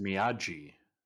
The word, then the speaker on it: Miyagi: I thought it was pronounced Miyagi. – It is Miyagi. – Oh, I was pronouncing it wrong this whole time.